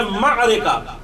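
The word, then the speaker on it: معرکہ: مارکا, اچھا ایک مارکا جو ابھی اس وقت آپ کے ذہن میں یہ وہ نہیں ہے, ایک مارکا وہ ہے جو عین سے ہے, مارکا ہے, اس کا صحیح تلفظ ہے مارکا, اور ایک الف کے ساتھ ہے مارکا.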